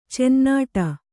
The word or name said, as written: ಚುನ್ನಾಟ